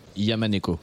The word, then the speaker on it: YMNK: Il y a aussi d'ailleurs d'autres artistes du Crossroads qui sont sur, sur le label, comme YMNK, je sais pas comment on prononce exactement son nom. – Yamaneko. – Yamaneko, donc, qui s'écrit YMNK, et Fig aussi, donc, qui a joué, qui a joué hier.